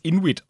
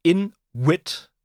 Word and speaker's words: Inwit: Inuit, äh, im Englischen könnte man es einfach als Inuit aussprechen, wegen des Ws. Ähm, klingt wie ein äh, dämliches Wortspiel, weil es ein fucking Eisplanet ist. Ah, Inuit, verstehe. – Ja, aber ich glaube, weil geschrieben I-N-W-I-T, Inuit ist tatsächlich ein sehr, sehr selten gebrauchtes englisches Wort, das in sich gekehrte Cleverness ausdrückt.